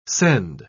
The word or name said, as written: scend